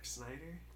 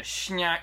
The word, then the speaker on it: Snyder: Can you say his name one more time? – Zack Snyder? – Okay. Just saying. Just checking. As you went on there, it turned into Schneider.